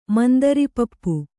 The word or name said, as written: ಮುಂದಿರಿಪಪ್ಪು